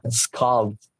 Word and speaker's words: Skald: O también una banda que se llama Skald, S-K-A-L-D.